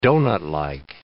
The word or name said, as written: doughnutlike